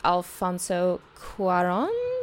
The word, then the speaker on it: Alfonso Cuarón: I know what you're going to go with, Alfonso Cuarón? – Yeah, I think you actually pronounced it correct more than I did, better than I did.